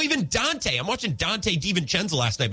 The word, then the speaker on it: DiVincenzo: By the way, no one outside of Bucks fandom can say his name. Like, Reggie Miller. Poor guy. And then I was listening to Bill Simmons' pod today. They're like, DiVincenzo? It's a V. It's not hard. Like, DiVincenzo. Could not be easier, guys.